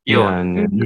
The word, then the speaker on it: Björn: How do you pronounce his name? – Yeah. Björn. It's bear in Swedish because he's from Sweden.